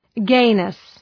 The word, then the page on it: gayness: ‘geınıs